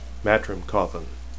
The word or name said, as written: mat